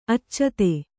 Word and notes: ಆಚದು: ācadu

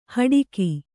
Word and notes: ಹಡಕೆ: Kannada

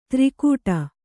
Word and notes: ತ್ರಿಗುಟ: tri guṭa

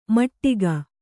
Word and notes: ಮೆಟ್ಟಿಕೆ: kannada

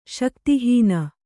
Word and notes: ಶಕ್ತಿಹೀನ: śakti hīna

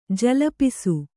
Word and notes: ಜಲಪಿಸು: jalapisu; Kannada

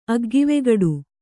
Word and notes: ಅಗ್ಗಿವೆಗಡು: kannada

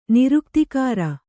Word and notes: ನಿರುಕ್ತಿಕಾರ: Kannada

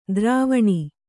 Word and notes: ದ್ರುವಣಿ: druvaṇi

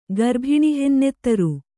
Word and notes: ಗರ್ಭಿಣಿಹೆನ್ನೆತ್ತರು: Kannada

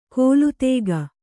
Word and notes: ಕೋಲುತೇಗ: kōlu tēga

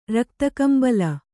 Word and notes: ರಕ್ತಕಂಬಳ: Kannada

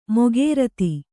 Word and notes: ಮುಖರತಿ: mukha rati

